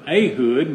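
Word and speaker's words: Ehud: Ahud, is how that's pronounced. I pronounced it wrong for a long time.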